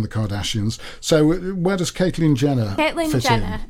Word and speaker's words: Caitlyn: Now, Caitlyn. – It's pronounced Caitlyn, is it? Okay.